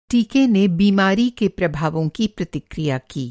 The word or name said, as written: प्रतिक्रिया